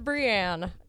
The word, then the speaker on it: Brianne: I have like two people in my life that call me Brianne. I say it like with a twang. Brianne. My name is Brianne.